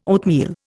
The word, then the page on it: oatmeal: Indian